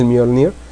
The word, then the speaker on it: Mjolnir: Ya checamos por ahí, es la pronunciación correcta, el Mjolnir.